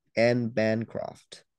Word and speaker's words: Anne Bancroft: It's B, Anne Bancroft. That's how you say it.